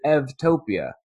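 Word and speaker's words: Eutopia: The reason for the pronunciation is that in Greek, the U is pronounced like a V. And we just, in English, we see the U, we pronounce it like a U, but in Greek, it's pronounced like a V. And so saying Evtopia instead of Utopia, it's easier to distinguish between the two.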